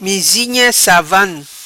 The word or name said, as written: Mizinha Savan